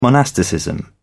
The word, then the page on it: monasticism: British English